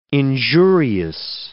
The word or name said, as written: injurious